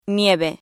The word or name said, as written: nieve